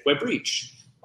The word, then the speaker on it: Web Reach: And there's a British guy who used to say, Web Reach. Web Reach, with the emphasis on the second syllable, and it sounded pretty nice.